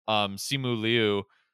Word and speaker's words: Simu Liu: Should probably first like pull us back to like, what did you think of Simu Liu? Did I pronounce that correctly? I hope I pronounced that correctly. – That's how I pronounce it and have always heard it.